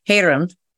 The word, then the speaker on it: Hyerim: I wanted to ask you about, and thank you for pronouncing it correctly, Hyerim, is that right? – Yeah, that's right.